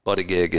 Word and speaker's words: Buttigieg: B-U-T-T-I-G-I-E-G.